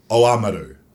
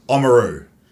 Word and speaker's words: Oamaru: But they don't even try and pronounce their name. They're like fighting to say their name the way they want to say it. – Yeah. – And it's, I think it's Oamaru. Oamaru.